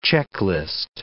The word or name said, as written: checklist